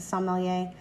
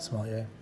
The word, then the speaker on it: sommelier: som, his sommelier. Um, is that how you pronounce it? – Sommelier. – Sommelier.